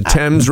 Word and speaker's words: Thames: the Tamas River. – The Thames River in England.